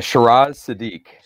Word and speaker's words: Shiraz Siddique: Yeah, Shiraz Siddique.